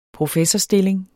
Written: professorstilling

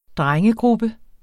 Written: drengegruppe

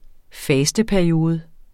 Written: fasteperiode

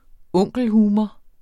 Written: onkelhumor

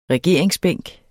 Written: regeringsbænk

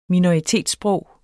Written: minoritetssprog

